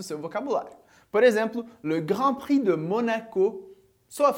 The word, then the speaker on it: Grand Prix: Vamos ao primeiro ponto da aula de hoje, como eu falei, que é as consoantes que não são pronunciadas no final das palavras. Primeiro exemplo aqui, a gente tem Grand Prix. Grand Prix.